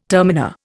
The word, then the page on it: terminer: Indian